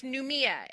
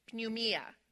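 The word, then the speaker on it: pneuma: So it's pneumia. And the way that that is spelled, just for those of you who like those things, is P-N-E-U-M-A. P-N-E-U-M-A, pneumia.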